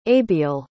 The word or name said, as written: abiel